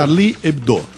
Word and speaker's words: Charlie Hebdo: Charlie Hebdo.